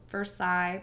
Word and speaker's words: Versailles: There's, there's a Versailles, Ohio that is spelled Versailles, but it's Versailles.